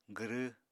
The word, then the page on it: gwrw: apatani